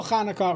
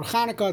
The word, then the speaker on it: Chanukah: So the classic, we know the brachas, the first bracha is Asherek Dishan, the Sais of Tzivan, the Halak Ner, Shel Chanukah or Chanukah, it's machlokes, how to say it, whichever one you do is fine.